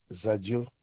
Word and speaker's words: Zadio: Zadio, Z-A-D-I-O.